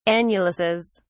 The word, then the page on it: annuluses: British English